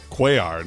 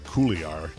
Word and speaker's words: Cuellar: Cuellar, not Cooliar.